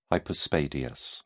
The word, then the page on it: hypospadias: British English